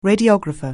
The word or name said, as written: radiographer